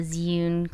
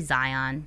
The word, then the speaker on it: Zhiyun: I say Zhiyun. – I hear a lot. – Zhiyun, but they say it like Zhiyun. – They say like Zhiyun. – Zhiyun. But yeah, people say Zion. James says Zion. That's totally off.